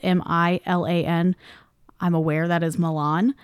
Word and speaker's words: Milan: Because she told us, she goes, I know it's spelled like Milan, but we say Milan kind of like Versailles.